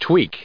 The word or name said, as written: tweak